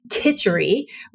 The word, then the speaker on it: khichdi: I pronounced it wrong. I said khichari. It's khichdi reset, what that is.